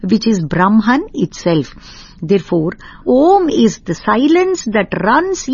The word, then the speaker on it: Om: And when we say that Om consists of three syllables, A, Vu, Ma, this Omkara, Om, encompasses the three states of consciousness itself.